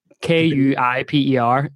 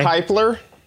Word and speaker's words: Kuiper: K U I P E R. You pronounce it a certain way. Kepler. Kepler belt. Well, says that. No, it's the Kuiper belt object.